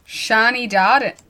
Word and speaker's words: Shani Darden: Shani Darden. I felt like I said that wrong, but I didn't.